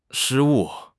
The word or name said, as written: 失误